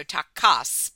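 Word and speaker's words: Takács: We should say I, Madman is directed by, I don't know how to say his name, I'm going to say Tibor Takacs. Yeah, the accent is over the second A, so Takas, but Takas.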